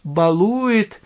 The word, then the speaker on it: балует: что Господь нас балует, обращая внимание на ударение, которое я употребил, балует, последний слог, ударный в глаголе баловать, баловать.